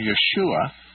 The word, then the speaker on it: Yeshua: And that Hebrew pronunciation, Yeshua, is transliterated in the Greek New Testament as Jesus. – So Jesus. – And so it's Yeshua is the Hebrew and Jesus is the Greek.